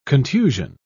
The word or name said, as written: contusion